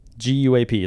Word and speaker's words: guap: GUAP. Is that how you pronounce guap.